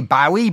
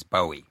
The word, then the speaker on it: Bowie: I always want to say Bowie, but that, and then people are texting in and saying, no, it's Bowie.